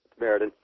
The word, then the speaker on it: Meriden: Here's Meriden, Connecticut. Am I saying that right? – Meriden.